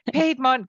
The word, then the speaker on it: Piedmont: Piedmont, P-I-E-D-M-O-N-T.